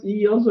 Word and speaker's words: EOS: uh, I I kept on saying iOS, and then when I watched it back, I was like, oh, it's EOS.